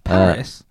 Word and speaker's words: Powys: Powys. Oh, Powys, sorry, I looked up how to pronounce this earlier.